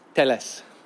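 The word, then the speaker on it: Telles: As I'm saying this, I'm getting pings to say that Teles, Alex Teles, that's how you pronounce it in Portuguese, Teles, he's from the Rio Grande do Sul in the south of Brazil.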